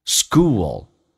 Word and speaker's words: school: in 跟 the 可以说 in a teacher in a school，记住长元音 school， L 这个发音很难发，舌尖抬起来一点点，靠上你的上牙齿后背一点点，叫 O school， school。